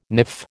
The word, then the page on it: niff: American English